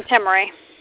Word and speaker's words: Timri: I'm Temri. – Temri, how do you spell that? It's an interesting name. – T-I-M-R-I. – T-I-M-R-I.